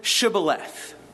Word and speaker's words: Shibboleth: They chose the word Shibboleth. And they chose that word because the Ephraimites could not pronounce the SH sound, the sh. All they could do was s.